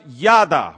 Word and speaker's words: yada: The word grief in the Hebrew is a little word pronounced yada, Y-A-D-A.